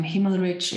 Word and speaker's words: Himmelrich: Okay, so it's Natalie, that's spelled N-A-T-H-A-L-I-E. And then my last name, Himmelrich, it's like H-I-M-M-E-L, which means heaven, actually.